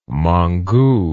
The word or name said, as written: mongu